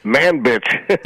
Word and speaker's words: Manbij: I'm just really sad I don't get to report on skirmishes in the town of Manbij anymore. So. – Manbij.